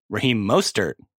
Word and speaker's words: Raheem Mostert: Sorry, Raheem Mostert that's how you pronounce it.